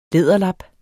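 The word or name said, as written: læderlap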